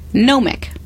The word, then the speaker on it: gnomic: Gnomic. – Gnomic. – Gnomic. – Like with a silent G or with no silent G? – Yes, good for you, silent G.